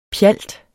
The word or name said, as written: PJALTERNES